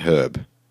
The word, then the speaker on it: herb: You know, Americans say twilight herb. That's weird, herb. Why don't you say the H? There's an H there. – Silent? – Silent H. It's not a silent H. We say a herb. They'll say an herb.